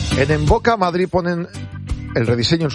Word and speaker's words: Mvoca: Y en Boca se escribe M-V-O-C-A. En Boca. Primera consulta, estudio gratuito. Está en Velázquez 96. M-V-O-C-A.